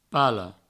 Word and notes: pala: palo

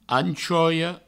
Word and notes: anchoia: anchoio

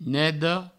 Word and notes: neda: nedo

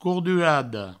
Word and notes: cordurada: courdurado

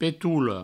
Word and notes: petola: Provençal; petoulo

